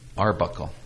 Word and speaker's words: Arbuckle: Arbuckle.